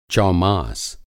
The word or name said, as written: चौमास